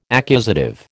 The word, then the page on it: accusative: American English